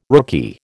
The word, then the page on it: rookie: American English